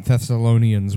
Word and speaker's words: Thessalonians: Second Thessalonians 1, because I'm second what? – Sorry. Thessalonians. – Oh, okay. – There we one. Go. – That's the right pronunciation of that.